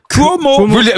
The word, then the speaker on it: Cuomo: Andrew Cuomo. – Vous, il prononce bien? – Oui, il ne sait pas. En plus, il est. – Si ça s'écrit C-U-O-M-O, il prononce bien. – Qu'est-ce qu'on en sait? – Oui, ça s'écrit C-U-O-M-O.